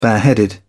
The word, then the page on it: bareheaded: British English